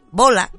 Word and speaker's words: Boya: Resulta que este muchacho cuando era adolescente, Bola se llama este muchacho, aunque se escribe Boya, por si lo queréis buscar vosotros, como les decía, resulta que cuando era adolescente le dijeron que era ilegal que estudiara matemáticas y ciencias en la escuela secundaria.